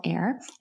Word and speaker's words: Aer: I call it the clit sucker, but it's spelled AER. It's called Air.